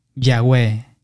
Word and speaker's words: Yahweh: Las transcripciones griegas también indican que YHWH debería pronunciarse Yahweh.